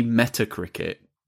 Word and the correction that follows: Metacritic: No, Metacritic. Metacritic. But I always say it wrong, so it's just now Metal Crickets.